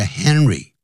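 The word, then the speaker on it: Henri: 所以以后把它翻成呢，这是一个形容词，就是叹为观止的，是这么叹为观止，如此的叹为观止 that the artist, 这位的画家，他的名字叫做 Henry, 如果是念成英文叫 Henry, de Toulouse Lautrec, 那可能是法文的这个念法呢，那不像英文，这并不是所有的美国人他懂法文的、啊，加拿大人也一样。那加拿大当然有一个去 Montreal, 在那地方他们是讲法语的。好，通常呢，就是直接也就会念成 Henry de Toulouse Lautrec, 这是英文的念法。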